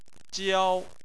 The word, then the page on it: jiao: 1